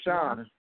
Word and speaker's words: Roshan: Again, is it Roshan or Rohan? – Layla Roshan. – Roshan. Okay. Yeah, I know I'm slow when it comes down to people's names, so don't come at me like that.